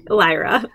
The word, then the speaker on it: Lyra: Lyra.